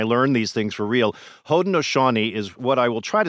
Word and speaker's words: Haudenosaunee: And for Haudenosaunee, it's Haudenosaunee.